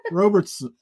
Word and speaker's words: Robertson: It's kind of like Robitussin, but Robertson.